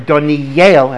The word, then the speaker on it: Daniel: And that is where Daniel, or Daniel, as we should properly pronounce it, was taken.